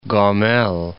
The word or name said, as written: Gomel